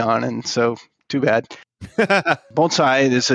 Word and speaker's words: bonsai: So the question of how to pronounce the word bonsai is, it's a very often mispronounced word, but the way I'm saying it is the correct way of saying it, bonsai. And I want to take a little... – So it's not bonsai!